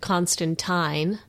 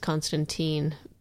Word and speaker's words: Constantine: We're going to be reviewing the pilot episode of Constantine, even though, I guess, due to American following, it's Constantine per the series. But I'm calling it Constantine. Rhymes with Valentine.